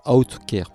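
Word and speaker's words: Autechre: Et puis on enchaînera avec Outcare, euh, ça se prononce Outcare parce qu'ils sont très. Euh, ils ont un esprit très mathématique.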